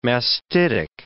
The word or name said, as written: mastitic